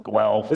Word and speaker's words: Guelph: Guelph. – This is not a joke. I really don't know. I'm sorry. – No one in the States can pronounce it properly. It's Guelph, Ontario.